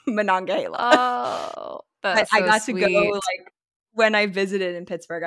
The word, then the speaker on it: Monongahela: And when you see it spelled out, it's, but it's Monongahela.